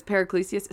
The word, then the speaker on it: Paracelsus: There was a footnote about I think I pronounced it Paracelsus or Pericles, but it's Paracelsus.